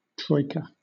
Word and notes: troika: British English

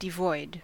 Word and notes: devoid: American English

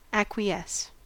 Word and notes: acquiesce: American English

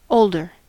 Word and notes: older: American English

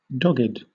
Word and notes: dogged: British English

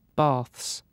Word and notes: baths: British English

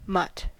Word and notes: mutt: American English

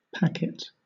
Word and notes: packet: British English

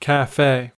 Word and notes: cafe: American English

